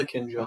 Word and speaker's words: Akinjo: Is that Akinjo? Is that my pronunciation? – It is Akinjo.